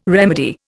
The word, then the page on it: remedy: Indian